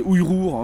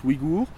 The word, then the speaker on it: Ouïghours: Et voilà, et elle est peuplée à 45% de Ouïghours, qu'il faut prononcer Ouïrours hein, si vous voulez, mais la plupart des gens maintenant prononcent Ouïghours.